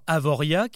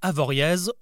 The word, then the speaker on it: Avoriaz: C'est ce qui nous donne aujourd'hui des noms comme Chamonix, qui ne se prononce pas Chamonix, pareil pour Avoria, qui ne se dit pas Avoriaz, ou encore Laclusaz, qui ne se dit pas Laclusaz.